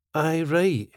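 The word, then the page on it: Aye right: Scottish English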